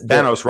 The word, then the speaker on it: Thanos: Thanos rhymes with Davos, by the way.